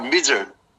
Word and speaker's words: Misr: In Egypt, one of the names of Egypt is, well, written in English, M-I-S-R, Mizr. – Mizr.